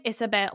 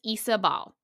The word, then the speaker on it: Isabel: It does look like Isabel. It's not. It's Isabel.